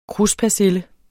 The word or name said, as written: kruspersille